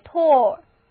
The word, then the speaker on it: tall: 我们一起来念这个词，中间的 A-L 字母组合发 O 的音，这个词就是 tall。